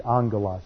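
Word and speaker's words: angelos (ἄγγελος): You have the same thing in angelos. It's alpha, gamma, gamma, but pronounced NG, angelos.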